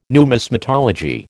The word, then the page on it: numismatology: American English